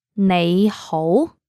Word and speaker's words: 你好: 你好. Let's say it slowly now. 你好. 你好. Now both of the syllables are using rising tones. 你好. The second one is slightly higher than the first one.